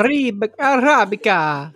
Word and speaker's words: Arabica: Arabica.